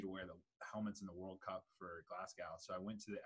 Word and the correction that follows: Glasgow: That's not how you pronounce it, buddy. – Pronounce it for me. – Glasgow. – Glasgow. Go. Glasgow. So you just drop the W.